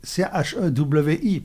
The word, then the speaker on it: Cherry: Cherry, Chewi, parce que c'est Cherry prononcé en américaine.